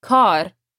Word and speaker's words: کار: Watch the word کار. It's a long A. کار.